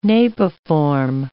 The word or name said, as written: napiform